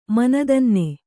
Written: ಮನದನ್ನ

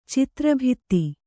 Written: ಚಿತ್ರಭಿತ್ತಿ

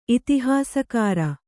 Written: ಇತಿಹಾಸಕಾರ

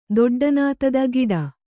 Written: ದೊಡ್ಡನಾತದ ಗಿಡ

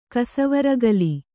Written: ಕಸವರಗಲಿ

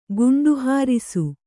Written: ಗುಂಡುಹಾರಿಸು

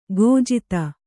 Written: ಕುಜಾತೆ

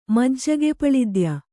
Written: ಮಜ್ಜಗೆಪಳಿದ್ಯ

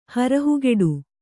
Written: ಹರಹುಗೆಡು

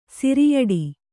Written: ಸಿರಿಯಡಿ

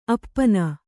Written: ಅಪಿನಿ

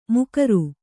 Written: ಮೊಗೇರ

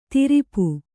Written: ದುರಾಪ

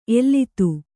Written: ಎಲ್ಲಿತ್ತು

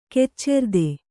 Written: ಕೆಚ್ಚೆರ್ದೆ

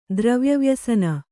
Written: ದ್ರವ್ಯವ್ಯಸನ

